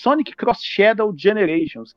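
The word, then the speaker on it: Sonic X Shadow Generations: No caso ali o X, né? A gente pronuncia como Cross. Sonic Cross Shadow Generations, que é basicamente o quê?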